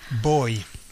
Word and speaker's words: voy: Now, boy. We'll start with boy, okay? Now, it starts with a V, but it's pronounced as a B, as all Spanish Vs are. So, boy.